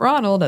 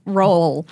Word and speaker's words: Roald: Not Ronald, as I thought growing up. – No. Roald. it's a Norwegian word or a Norwegian name. It's both, I suppose. I'm pretty sure there's an extra, like, syllable worth of vowel sound that I'm not pronouncing correctly at the front of it, but... – But she is not saying Ronald. – No. – Which it absolutely is not.